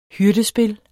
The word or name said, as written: hyrdespil